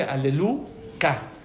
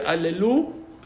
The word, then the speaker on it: Haleluya: Entonces, por eso se dice aleluca. Cuando ya estás rezando, no dices aleluca, dices aleluya. Pero cuando no estás rezando y cuando estás cantando o cuando estás hablando de la palabra, no se dice aleluya, sino aleluca. ¿Por qué? Porque está el nombre Hashem.